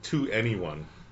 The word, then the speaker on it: 2NE1: 2-N-E-1. – How did they pronounce it when they were... – 21. – They did pronounce it 21? – Yes. – Because I kind of like 2-N-E-1.